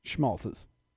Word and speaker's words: Schmaltz's: Say it like you're supposed to. – Schmaltz's. – There you go.